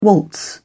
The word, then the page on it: waltz: British English